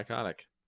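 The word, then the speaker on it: Kotyk: And if you choose to, you can find me on social media at my name, Bri Kotick.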